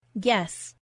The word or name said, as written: guess